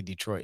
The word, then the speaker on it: Detroit: That's how they say it in Detroit. They don't say Detroit. They say Detroit.